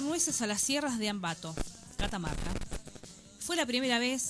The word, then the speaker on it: Ambato: En las sierras de Ambato es, o es Ambato, no sé cómo se dice de Catamarca. – Ambato.